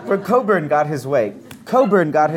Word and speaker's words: Cockburn: Cockburn got his way, but not until 1880. – It's pronounced Coburn.